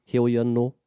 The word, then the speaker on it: heoi anō: There's a few ways that this kupu can be pronounced. It is just depending on the speaker and where they come from. So heoi could be said hoi, hoi, heoi, hoi, heoi anō.